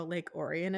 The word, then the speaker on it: Lake Orion: And I was like, O-R-I-O-N. And he was it doesn't matter. They're just pronounced differently. It's like read and read.